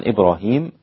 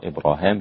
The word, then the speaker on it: إبراهيم: طبعا إبراهيم على نفس الرسم وعلى نفس النطق أيضا، ليس كما قرأناها في سورة البقرة إبراهام للإمام ابن عامر، لكنها في سورة آل عمران ليس فيها إبراهام. إن